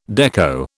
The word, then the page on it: dekko: British English